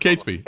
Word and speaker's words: Catesby: Yeah, I frankly have to admit, I don't know how to pronounce his name. – It's Catesby. – Catesby. – Yeah, Catesby. – Catesby it is.